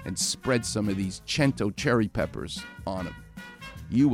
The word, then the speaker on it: Cento: And the company is called Cento, C E N T O. It's C E N, pronounced ch, because it's Italian.